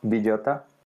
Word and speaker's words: Villota: So my name, if you say it in Spanish, is Luis Villota.